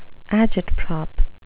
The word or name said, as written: agitprop